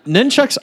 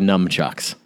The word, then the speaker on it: nunchucks: But two, it's also the number one most mispronounced ninja weapon, where people call it like nunchucks. – Yeah. – Right. – They change the N to an M, and you're just like, well, you're a numbskull if you think that's how they're pronounced.